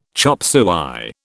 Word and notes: chop suey: British English

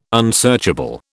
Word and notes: unsearchable: British English

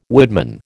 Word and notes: woodman: American English

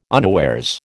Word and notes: unawares: American English

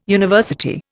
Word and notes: university: Indian

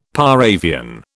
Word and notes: par avion: British English

